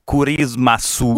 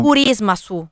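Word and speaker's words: Curismasu (クリスマス): No, ci vuole la U. Curismasu. – Curismasu. – Perché la lingua è fatta a sillabe, no? Quindi c'è la vocale sempre dopo la consonante.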